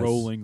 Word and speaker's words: Rowling: Rowling, like bowling, I believe that's how it's pronounced.